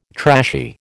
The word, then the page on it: trashy: American English